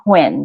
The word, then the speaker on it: Huynh: Nguyen. That's spelled H-U-Y-N-H.